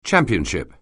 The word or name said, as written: championship